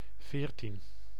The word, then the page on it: veertien: [veːɾtin]; /ˈveːr.tin/; /ve:ɾtin/